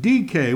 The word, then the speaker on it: dike: In English letters, it is spelt D-I-K-E. It is pronounced D-K.